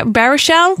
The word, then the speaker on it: Baruchel: Baruchel? – Yes, there you go. Good job. – Jay Baruchel, who we love in Goon, she said, really pulled off the New Englander's accent.